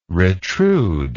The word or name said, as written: retrude